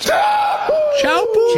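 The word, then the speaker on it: Teahupo'o: And I believe it's pronounced Chaupu.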